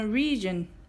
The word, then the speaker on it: region: この region は region の二分節です。最初の RE は、れではなくて、 re と発音します。 re ですね。で、 gion は、 john、 j o です。